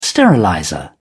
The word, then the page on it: sterilizer: British English